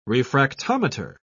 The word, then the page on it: refractometer: British English